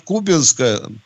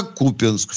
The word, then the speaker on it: Купянск: радиослушателя поясню, что по части произношения он совершенно прав, ибо в русском языке удаление сдвигается к концу слова. И еще великий Симонов писал в одном из своих стихотворений «В боях под Купянском в июле». Угу. Все правильно ударение перемещается.